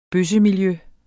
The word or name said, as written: bøssemiljø